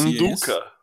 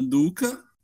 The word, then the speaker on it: Nduka: Aí Catalina Garcia do Chile, temos também Brianna Brand, Alexander Jaksic, que é um alemão, pelo que eu entendi aqui, o EJ Nutka, não sei como é que pronuncia Nduka. – isso. Nduka. E o Teut Miles.